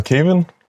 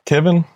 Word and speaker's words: Kevin: Jamen, øh, jeg hedder Kevin. Kevin, hvordan du end vil udtale det, så øh, vi ser egentlig bare Kev.